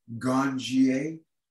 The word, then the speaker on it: ganjier: Let, let, me say it just so I can say it correctly. Ganjier. Ganjier. – Yes, like ganjier. a sommelier for wine.